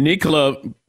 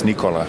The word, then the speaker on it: Nikola: It's Nikola. – Vucevic says we have been mispronouncing your name all along. It is not Nikola. It's Nikola.